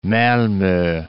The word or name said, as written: Malmo